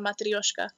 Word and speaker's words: matryoshka: They would say matryoshka. If you went to Russia, they'd call that a matryoshka. – Oh my God, you pronounce it so much better. I was trying so hard to roll my R's.